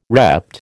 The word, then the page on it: repped: American English